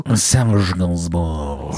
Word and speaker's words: Serge Gainsbourg: Und zwar, den kennst du auch, Serge Gainsbourg. – Serge Gainsbourg.